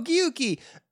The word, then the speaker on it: Gyuki: G-Y-U-K-I.